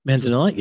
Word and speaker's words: Manzanilla: It's the, I hope I pronounced this correctly, it's the Manzanilla edition. – Manzanilla, yeah.